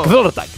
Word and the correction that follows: Kvelertak: és que no sé com pronunciar una K i una P baixa de seguida, saps? Kvelertak. – Kvelertak, és que és així, aquests noruecs no fotem.